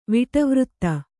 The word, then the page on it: ವಿಟವೃತ್ತ: kannada